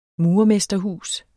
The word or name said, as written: MURERMESTERHUS